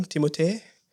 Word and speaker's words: Timothée: How do you pronounce his name? Timothée. And they're making a second one soon.